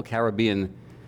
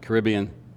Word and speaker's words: Caribbean: And so we planned, and we prepared, and we were ready to go, and we're looking, thinking about these wonderful Caribbean, or if you, depending how you pronounce it, Caribbean, palm trees that we're about to fly over to on this island.